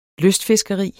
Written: lystfiskeri

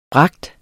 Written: bringe

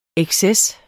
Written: exces

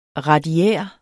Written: radiær

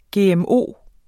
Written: GMO

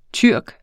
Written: tyrker

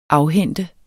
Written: AFHENTES

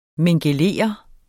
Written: mingelere

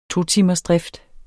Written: totimersdrift

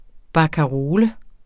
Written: barkarole

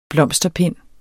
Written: blomsterpind

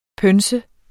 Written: pønse

